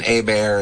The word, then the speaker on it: Hebert: Well, yeah, my last name being Hebert and spelled like Hebert, it's been everything yes. from Hebert to Herbert to Sherbert to Schubert.